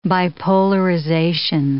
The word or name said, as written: bipolarization